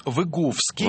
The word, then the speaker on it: Выговский: Выговский, Выговский, потому что, ну, по-польски, по крайней мере, Выговский. На предпоследний слог ударения, да, Выговский.